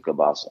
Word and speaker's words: Kielbasa: You got them right where you want them, Mr. Kielbasa.